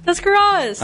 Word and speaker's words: Tuscarawas: Tuscarawas.